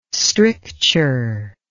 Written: stricture

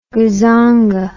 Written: gazonga

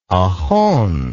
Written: ohone

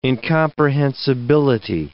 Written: incomprehensibility

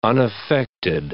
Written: unaffected

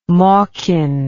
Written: mawkin

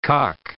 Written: cock

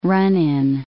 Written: run-in

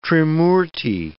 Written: Trimurti

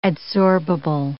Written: adsorbable

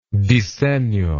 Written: disegno

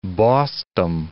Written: bossdom

